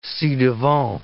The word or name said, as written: ci-devant